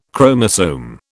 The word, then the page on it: chromosome: British English